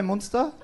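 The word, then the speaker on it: Münster: Is that how you pronounce Munster? It's got an umlaut over the... What the... What? – Munster. – Munster. Auf Deutsch, ja?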